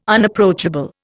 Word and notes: Unapproachable: Indian

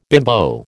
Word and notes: bimbo: American English

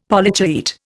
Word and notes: Polychaete: Indian

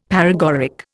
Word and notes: paregoric: Indian

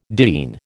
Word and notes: diene: American English

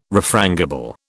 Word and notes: refrangible: British English

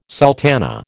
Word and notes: sultana: American English

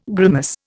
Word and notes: grimace: Indian

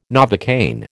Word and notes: Novocaine: American English

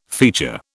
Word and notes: feature: British English

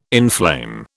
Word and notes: inflame: British English

